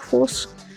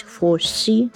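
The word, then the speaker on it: Fosse: Кстати, важный момент, как обнаружилось в последние годы, фамилию, которую мы традиционно произносили как Фосс, на самом деле следует читать Фосси, и на конце читается.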